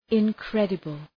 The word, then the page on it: incredible: {ın’kredəbəl}